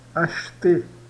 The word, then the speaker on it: acheter: Acheter. Esse aqui ele fica mudo, tá? Acheter.